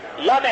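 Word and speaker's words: La (لا): Lam elif diyoruz. Okurken La diye okuyoruz. İsmini söylerken Lam elif diye söylüyoruz. Okunuşu La, La ilahe, bakın La, La, böyle okunuyor.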